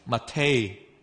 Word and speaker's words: mate: 嚟到阿根廷嘅第四站啦，嗱，阿根廷人咧就好似我哋中国人呢一都会饮茶噶，咁但系我哋饮嗰啲咧就系普洱水仙铁观音，佢哋饮嗰只茶咧叫马黛茶，正确嘅读音或者我参考咗 Google 嘅读音就系读 mate，咁啊每个阿根廷人调制 mate 嘅方法咧都唔同嘅，咁以下。